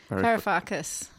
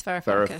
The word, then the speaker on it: Varoufakis: Varoufakis. Yanis Varoufakis.